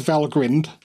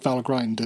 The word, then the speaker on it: Valgrind: So it is Valgrind. And not Valgrind, as many people do say.